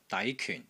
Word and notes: DAI2 KYUN4: Cantonese

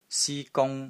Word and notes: SI1 GUNG1: Cantonese

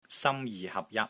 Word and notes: SAM1 JI3 HAP6 JAT1: Cantonese

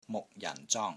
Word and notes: MUK6 JAN4 ZONG1: Cantonese

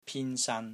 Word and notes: PIN1 SAN1: Cantonese

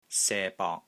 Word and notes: SE1 BONG2: Cantonese

